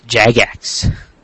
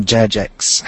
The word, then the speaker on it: Jagex: It's pronounced Jagex. – Yeah, not Jajex.